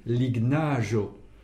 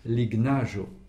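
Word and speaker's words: lignaĵo: Et j'insiste aussi sur le GN, qu'on ne prononce pas comme en français. En espéranto, je rappelle que toute lettre se prononce de la même manière, quelles que soient les lettres qui précèdent ou qui suivent. Donc, lignaggio. Je prononce le G, puis le N, sans les lier comme on le fait en français.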